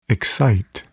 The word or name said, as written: excite